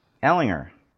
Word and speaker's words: Ehlinger: Ehlinger.